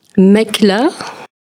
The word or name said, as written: Maqla